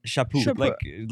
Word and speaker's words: Chaput: Michael Chaput. Like shampoo.